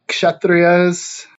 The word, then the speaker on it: Kshatriyas: Kshatriyas, are the warriors.